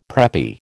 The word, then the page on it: preppy: American English